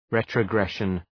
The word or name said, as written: retrogression